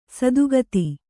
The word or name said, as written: ಸದಾಗತಿ